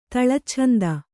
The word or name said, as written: ತಳಚ್ಛಂದ